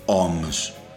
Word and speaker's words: Ohms: Los Deftones le ponen OMS, que se escribe O-H-M-S. Deep Purple le pone Whoosh, W-H-O-O-S-H, -O sea, OMS, Whoosh ping, pum, cabón.